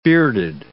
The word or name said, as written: bearded